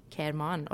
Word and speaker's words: Kerman: Habe ich es richtig ausgesprochen? Kerman, oder? Ja. Ja.